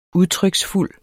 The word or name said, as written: udtryksfuld